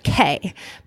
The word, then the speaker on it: Caye: Caye, but it's spelled with a C, C A Y E.